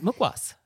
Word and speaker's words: mukhwas: So this is mukwas.